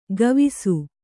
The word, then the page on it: ಗವಿಸು: kannada